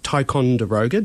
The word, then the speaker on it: Ticonderoga: And in the spring of 1852, the emigrant ship Ticonderoga, did I pronounce it right? – Sure sailed through the heads of Port Phillip Bay after a three-month voyage from Liverpool in the UK.